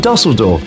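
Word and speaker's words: Düsseldorf: The Tips, of course, are from Dusseldorf. – Dusseldorf? – You even say it correctly.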